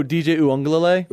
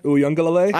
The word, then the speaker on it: Uiagalelei: Oh, DJ Uiagalelei. – Uiagalelei.